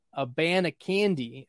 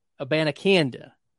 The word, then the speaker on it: Abanikanda: That's how you pronounce it. It's not a band of candy. It's not a band of conda. It's Israel, a band of canda.